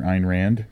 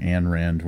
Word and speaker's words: Ayn Rand: It involves Time Pimp, the panda named Professor Panda, Sylvia Plath, and the author Ayn Rand. Which we got yelled at on a previous episode for saying Ayn Rand when her name is pronounced Ayn Rand.